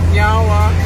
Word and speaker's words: nyawa: So in our language, we say nyawa, which means thank you. – Nyawa. – Nyawa. – I said it right? – Yeah, you did.